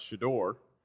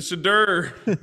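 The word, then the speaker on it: Shedeur: I have to laugh, Jake, because I had just got done trying to educate everyone on how to pronounce Shador. And then Rick Ross drops the video, do the Shadur. Yes. I'm like, well, I can't, you know, I'm not going to be the one to tell Rick Ross he's pronouncing it wrong.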